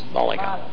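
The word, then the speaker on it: Málaga: Malaga. Accent on the first syllable. Malaga.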